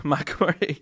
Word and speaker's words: Macquarie: Now, this one is going to be hosted by the Governor Marquis Memorial Pipe Band. Let's probably pronounce that wrong again. – Stuff that Rab got wrong. – Yeah, the Governor Macquarie Memorial Pipe Band from Sydney, Australia, are hosting an online contest, which is going to be something really quite unique.